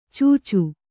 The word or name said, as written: ಚೂಚು